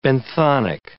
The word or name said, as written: benthonic